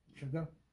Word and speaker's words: Shevchenko: Shevchenko, right? – Shevchenko.